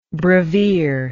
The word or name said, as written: brevier